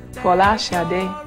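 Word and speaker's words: Folasade: I was born in Nigeria, in somewhere called Ibadan, and my name is my true Nigerian name, or the true Nigerian pronunciation, or as close as I can get to it, is Fola Sade, which means crowning glory, and it was abbreviated.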